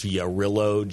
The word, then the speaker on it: Jarrylo: I think it's pronounced Jarrillo, J-A-R-R-Y-L-O. – Okay.